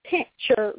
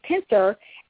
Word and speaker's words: pincer: And again, it's not pincher grasp. I hear a lot of people mispronounce that word. It's pincer.